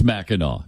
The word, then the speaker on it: Mackinac: First of all, It's spelled, why do they spell it Mackinac when it's Mackinac? It doesn't make any sense.